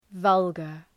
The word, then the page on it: vulgar: ‘vʌlgər